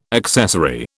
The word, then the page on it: accessary: British English